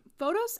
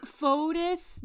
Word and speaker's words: Fotis: Now, at this time, Photos and Je- Phod- his name is so hard to pronounce. I always want to say photos.